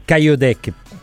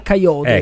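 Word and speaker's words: Kayode: Altro giovane di cui ogni volta sento parlare, ma sinceramente io l'ho visto forse una volta, è Caio Dec, pronuncio malissimo, no, sicuramente. – Sì, allora, cioè, lui ha chiesto di essere chiamato all'italiana Coyote, ecco, anche perché lui è italiano, gioca nell'Under, come vedete, 19, lo pronuncio Zuda.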